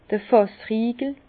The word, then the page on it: d'r Fàssriegel: Bas-Rhin; Herrlisheim